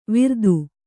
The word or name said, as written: ವಿರ್ದು